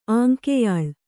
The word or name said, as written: ಆಂಕೆಯಾಳ್